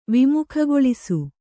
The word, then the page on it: ವಿಮುಖಗೊಳಿಸು: vimukhagoḷisu